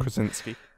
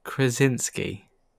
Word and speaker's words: Krasinski: Krasinski. – All right, let me see if I can do it. Krasinski.